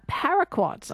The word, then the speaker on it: paraquat: We are going to talk, though, about a herbicide, paraquat. I think you can correct me if the pronunciation's incorrect. – No, you got it absolutely right there.